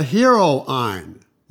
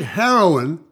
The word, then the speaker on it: heroine: Is that the hero Ayn? Now, I meant heroine, but I didn't know how to pronounce it in those days. So I said, hero Ayn.